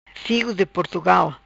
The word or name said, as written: Figo de Portugal